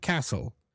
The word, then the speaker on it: castle: And to finish with this segment, the T, the silent T, one pattern that we could find for the silent T occurs when it is paired with the letter L in words like whistle, bristle, thistle, bustle, hustle, and castle.